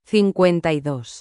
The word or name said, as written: 52